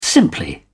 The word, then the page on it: simply: British English